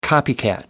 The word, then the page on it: copycat: American English